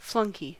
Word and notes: flunkey: American English

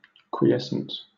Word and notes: quiescent: British English

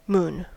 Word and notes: moon: American English

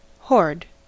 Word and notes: hoard: American English

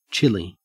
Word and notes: chilly: Australian English

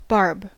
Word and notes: barb: American English

